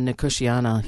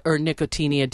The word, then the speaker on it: Nicotiana: Nicotiana. Yeah. – Nicotinia, depending on how you like to pronounce it.